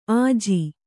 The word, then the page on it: ಅಜೆ: kannada